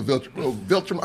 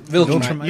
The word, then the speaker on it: Viltrum: And my father was a Viltrum. I can never pronounce it. – Viltrum. You said it right.